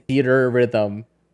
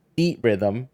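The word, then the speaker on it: Theatrhythm: I think that's the way the problem is that you are saying theater and anyway, like you're pronouncing the R twice in Theater Rhythm and they've mashed it into one word. So it should be Theat Rhythm.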